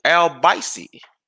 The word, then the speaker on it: Albicy: Andrew Albicy, which rhymes with spicy, plays for Gran Canaria.